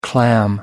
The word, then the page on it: clam up: American English